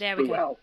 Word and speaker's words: Bual: If you pronounce with a U in Portuguese, it's pronounced like an O anyway. – So... – So the English write Buell. – Yeah. Okay. – So we write Buell, B-U-A-L. They write Boel, but it's still pronounced Buell. So whether it's an O or not, it's always pronounced Buell.